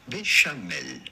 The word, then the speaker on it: béchamel: How do you go about pronouncing it in French? Because this is certainly a French word. This would be pronounced béchamel.